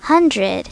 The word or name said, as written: hundred